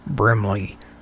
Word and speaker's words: Brimley: W-I-L-F-O-R-D. – Yes. – And what's the last name? – Brimley. – Can you spell it for me? – B-R-I-M-L-E-Y.